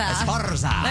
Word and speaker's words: Esparza: I'm trying to roll my R's. – You need to roll your R's better. – Esparza. There we go.